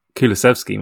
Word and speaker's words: Kulusevski: Well, I don't know who Kluwiewski is, mate, but Kulusevski might be good.